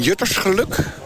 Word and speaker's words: Juttersgeluk: Nou, we staan hier bij mensen van Juttersgeluk. Zeg ik dat goed? – Ja, dat klopt.